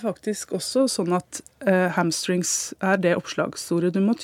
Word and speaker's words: hamstrings: Eh, og dette her antar jeg er, og du er ikke aleine om det, å kalle dette for hamstring. – Nei. Eh, det er en leseuttale, og den brukes så spesifikt om akkurat akkurat denne muskelgruppa. Det er, det er, litt snodig, men, men jeg forstår også at, at, folk tyr til den eh, uttalen. Men det er altså hamstrings, skinkestrenger.